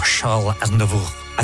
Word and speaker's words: Charles Aznavour: Charles Aznavour. I think that's how you actually pronounce his name.